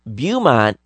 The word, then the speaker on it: Beaumont: Beaumont is pronounced like bow. So used to shoot arrows. So Beaumont. Beaumont is of a French origin.